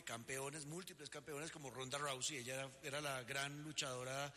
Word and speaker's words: Ronda Rousey: La opinión sobre el publicitado combate entre Ronda Rousey, ¿se pronuncia? – Ronda Rousey, sí.